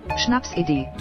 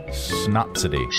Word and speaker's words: Schnapsidee: How do you pronounce that word? – Schnapsidee. – Schnapsidee? Schnapsidee.